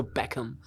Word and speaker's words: Beckham: Ty jsi to krásně vyslovil, jak jsi řekl to Beckham. – Beckham.